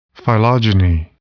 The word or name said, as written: phylogeny